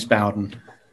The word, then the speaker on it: Bowden: James Bowden. – James, how do you spell that? – B-O-W-D-E-N.